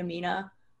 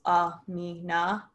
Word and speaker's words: Amina: But I saw that Queen Amina, I believe, oh my gosh, it's Amina. So sorry again if I'm pronouncing any of these words incorrectly.